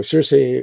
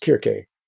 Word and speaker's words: Circe: The C's are pronounced K's in the original Greek. So Circe was actually pronounced Kirke.